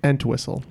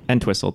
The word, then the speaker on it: Entwistle: That might be Peter Elswhistle or whatever, however you say his name. Great. – Entwistle. – Entwistle, thank you.